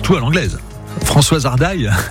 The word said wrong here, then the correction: Hardy: alors peut-être. Non, Françoise Hardy, très bien.